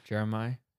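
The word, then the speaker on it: Jeremih: Jeremiah? – Yeah.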